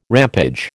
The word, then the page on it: rampage: American English